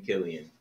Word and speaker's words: Cillian: Sometimes it's an Irish name. It's an old Irish name. It I think like most Irish names, they were saints originally, and. And it's pronounced Killian.